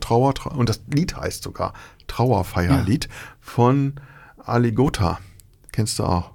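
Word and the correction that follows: Alligatoah: Habe ich den jetzt falsch ausgesprochen? – Ja, ich glaube einfach Alligator. – Wie das Tier.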